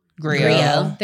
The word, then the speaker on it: Griot: Is it Giro? How do you say it? G-R-I-O. – Griot.